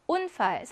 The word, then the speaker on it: Unfall: Unfall, 사고란 뜻이고요. 강세는 당연히 이름절에 있습니다. 강세 모음 뒤에 그러면 자음의 개수 일단 세어볼까요? 몇 개예요? 두 개가 나오죠. 이럴 경우는 짧은 우 발음이죠.